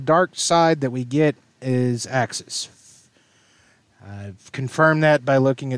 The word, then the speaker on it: Uxas: This is Axis, which is the pronunciation, and then the actual spelling of it is kind of like Bologna and Bologna. Axis is spelled UXAS,